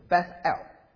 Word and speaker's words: Beth El: 那为什么把它改名叫做伯亚文呢？因为伯特利这个字哈，它的希伯来语的念法是 Beth El， Beth B-E-T-H，这个意思是家， Beth El， El 是神的意思，阿拉伯人就念成阿拉，阿拉伯语跟希伯来语是一种同一个语言体系的，所以 El 跟 Allah 是同一个字，所以 Beth El 是神的家。